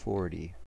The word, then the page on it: forty: American English